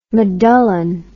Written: medullin

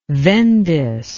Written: vendace

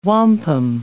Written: wampum

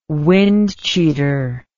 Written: windcheater

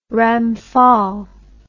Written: Ramphal

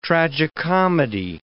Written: tragicomedy

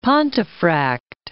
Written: Pontefract

